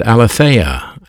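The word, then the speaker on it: aletheia (ἀλήθεια): The English word truth, T-R-U-T-H, is pronounced different in the Greek New Testament. It's the word aletheia, aletheia, A-L-E-T-H-E-I-A, aletheia.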